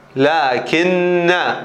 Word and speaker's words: لكن: ولذا في القرآن الألف تكتب لكن، ولكن عليه دائرة، هذه الدائرة يعني الألف ما تلفظ فقط تكتب. ليش ما تلفظ؟ لأنها كأنا ما تلفظ، أن هذه الألف هي ألف أنا، فمكتوبة في رسم القرآن ولكنها لا تلفظ، فتقرأ لكن هو الله ربي. الهمزة حذفت اعتباطا، لا كلامي عن الألف، هذه الألف مكتوبة ولكن لا تلفظ، عليها دائرة، هذه علامة الحرف الذي يكتب ولا يلفظ.